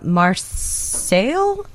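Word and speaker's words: Marseilles: from Marseille, Illinois? – Yep. – Am I saying that right? – Yeah, yeah.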